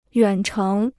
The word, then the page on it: 远程: yuǎn chéng